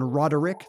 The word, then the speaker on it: Roderick: John Roderick, three syllables.